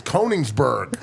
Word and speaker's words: Koningsberg: Got my name wrong for about 10 years. – Yep, 10 years. He had to remind me openly, like, my name is Koningsberg.